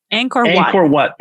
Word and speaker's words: Angkor Wat: So I went to the temples of Angkor Wat. Is that how you say it? How do you say it? – Angkor Wat.